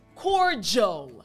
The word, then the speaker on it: cordial: And for the L sound, you really want to make sure once again that your tongue is touching the roof of your mouth. So again, jol. Excellent. So the entire word once again will be cordial.